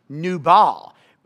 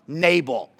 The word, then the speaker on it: Nabal: I think his name is actually pronounced Nubal, but that is way too fancy for me, so we're just going to call him Nabal, kind of like Mabel, but Nabal, okay?